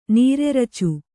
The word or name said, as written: ನೀರೆರಚು